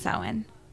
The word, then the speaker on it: Samhain: But I do know that Samhain, for the pagan wheel of the year, it's pronounced Samhain.